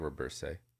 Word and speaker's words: Robberse: Uh, how would you say that? R O B B E R S E. Roberse. Reberse. Yeah, I like Reberse.